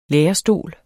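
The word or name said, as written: lærestol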